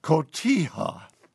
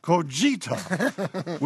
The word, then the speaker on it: Cotija: I did notice on a recent recipe you mentioned the Mexican cheese cotija. However, you pronounced it multiple times as Cogita, which means little limping girl. Instead of Cotija, which is named after a town in Mexico.